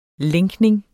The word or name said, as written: lænkning